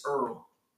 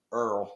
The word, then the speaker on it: Errol: Hey, Errol, what's your top five hot sauces of the year? – It's Errol. – Errol.